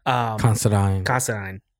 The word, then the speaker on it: Considine: Considine.